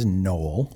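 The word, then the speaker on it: gnoll: So the proper pronunciation of the creature's name is Noel.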